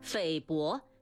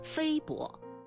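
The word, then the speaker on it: 菲薄: 匪薄请不要读成非薄。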